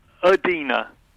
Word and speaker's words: Idina: It's actually, yes, it's Idina.